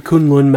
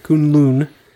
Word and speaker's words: Kunlun: Well, there is a mountain range in China called the Kunlun Mountains. Kunlun. That's how they pronounce it. It's Kunlun.